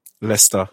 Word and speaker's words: Leicester: Um, how do you say Leicester? Leicester.